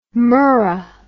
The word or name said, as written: murrah